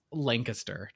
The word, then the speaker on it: Lancaster: The citizens banded together to I pronounced it at a bar and somebody was like, it's Lancaster, just so you know, Lancaster. And I was like, oh.